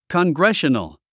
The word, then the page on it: congressional: kənɡréʃənl; コングレショナル